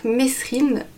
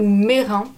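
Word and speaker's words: Mesrine: Jacques Messrine, ou Mérin, il y a deux prononciations pour son nom de famille, est né le 28 décembre 1936 à Clichy, près de Paris.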